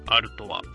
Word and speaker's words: Artois: で、ラベルが貼ってあって、こう、赤い、背景に白い文字でステラアルトワ。これはちょっとアルトワって読めないんですけどね、日本人の人は。 – うん、読めない。 – ARTOIS でアルトワって読みますね。